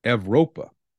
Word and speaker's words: Evropa: Identity Evropa, I believe is how you pronounce that. It's spelled E-V-R-O-P-A.